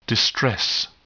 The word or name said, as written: distress